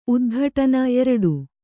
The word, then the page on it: ಉದ್ಘಾಟನೆ: kannada